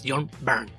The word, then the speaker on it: Byrne: La Y se pronuncia como una U suave, como en el verbo quemar en inglés, es John Byrne.